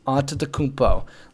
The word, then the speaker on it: Antetokounmpo: Number four, Giannis Antetokounmpo. Yes, Antetokounmpo. Look it up. It says it on YouTube. There's many videos on how to say it.